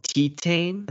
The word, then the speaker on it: Titane: It's called Titane. I think it's how you pronounce it. – How do you spell it? – Titane. So it's French for titanium.